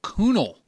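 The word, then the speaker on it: Kuhnel: and Joel Kunnel, I believe that's how you pronounce his name. Is that right? – That is right.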